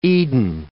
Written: Eden

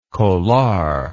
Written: kolar

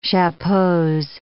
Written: chapeaus

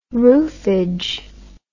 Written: roofage

